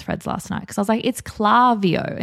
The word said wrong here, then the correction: Klaviyo: And they're like, no, it's Klaviyo.